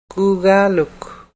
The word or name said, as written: kuugaaluk